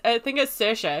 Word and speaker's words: Saoirse: I think it's Saoirse because I've heard her on like... Yeah, Jimmy Fallon or something, you know, one of those night shows, say her name. I'm pretty sure it's Saoirse.